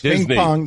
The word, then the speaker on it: Disney: You have this weird knack of turning a two-syllable word into a three-syllable one. – ESPN? – No. – Disney. – You make it Disney World. – Yeah, it's a tough one for me. I don't know what to tell you this. Disney. – There you go. Two syllables.